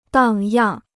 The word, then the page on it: 荡漾: dàng yàng